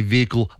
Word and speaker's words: vehicle: because he was one of the few people I knew who used to say vehicle. – Yep. – Like very prominently pronounce the H.